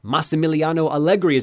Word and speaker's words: Massimiliano Allegri: Massimiliano Allegri is in charge again, like he was up until 2019. Bill, back to you. – You were so proud of that pronunciation.